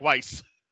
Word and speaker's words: Weiss: Brad introduces him and Janet, and I love how he gets Janet's last name wrong. He's like, "Janet Weiss," and she's like, "Weiss."